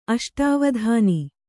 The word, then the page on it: ಅಷ್ಟಾವಧಾನ: aṣṭāvadhāna